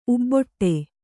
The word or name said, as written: ಉಬ್ಬೊಟ್ಟೆ